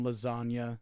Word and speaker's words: lasagna: The G is silent, yeah, in lasagna, like real G's, real gangsters.